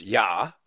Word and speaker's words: iah: All of these prophets and scribes and other people as well are surnamed by Yahweh, by the letters I-A-H, pronounced, of course, Yah, at the end of these people's names.